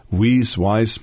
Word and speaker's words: Wiese: In German, you pronounce him Wiese, it's a German name, yes. Weiss, no idea.